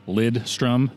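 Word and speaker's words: Lidström: Lidstrom, there is no N in there. – Lidstrom. – L-I-D. – Lidstrom. – S-T-R-O-M.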